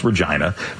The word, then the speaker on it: Regina: It looks like it's spelled Regina, but it is pronounced Regina.